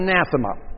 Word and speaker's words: anathema: By the way, an anathema, that is pronounced anathema. It's an Aramaic word.